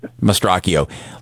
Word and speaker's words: Mastrocchio: And you have to listen very closely, but this is the full sound cut, and listen to how Anne-Marie Mastrocchio, did I say it right that time, Mastrocchio? Mastrocchio? – Yep. – Mastrocchio.